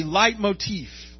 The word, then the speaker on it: leitmotif: This is technically called a leitmotif. And if you want to Google it, it's spelled L-E-I-T-M-O-T-I-F-F.